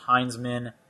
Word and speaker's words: Hinzman: My last name is pronounced Heinzman, and yes, it's weird misspelling my last name. I don't. – It's okay. Spelled Hinzman. That's why I said it that way.